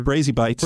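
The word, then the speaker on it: Brazi Bites: So also Bratzy Bites, they're Brazy Bites. – Brazy Bites. – Yes. – Okay. I didn't. – You're doing the Italian pronunciation, but they're Portuguese.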